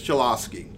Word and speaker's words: Cholowski: And with that said, let's bring him in, one of the wildly popular young Red Wings / Griffins, Dennis Cholowski.